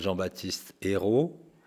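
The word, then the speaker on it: Jean-Baptiste Eyraud: Moi, je m'appelle Jean-Baptiste Hérault. – Tu l'écris comment? – Je l'écris E-Y-R-A-U-D.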